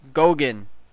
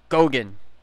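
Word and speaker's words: Goguen: And after that, after you make gains in that, my favorite, Cardano, ADA, Charles Hoskinson, Gauguin. That's how you pronounce it. Gauguin.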